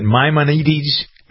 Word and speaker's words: Maimonides: Is it Maimonides Medical Center? How do you pronounce that? – Yes, hi.